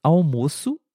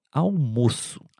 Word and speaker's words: almoço: Na primeira frase, o almoço, almoço é um pouquinho mais fechado, almoço.